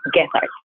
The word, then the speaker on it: Gethard: I am trying to model after that glorious, heroic example of Chris Gethard.